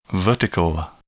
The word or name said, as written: vertical